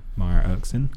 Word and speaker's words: Maya Erskine: Maya Erskine.